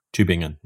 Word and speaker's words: Tübingen: Tübingen, yes.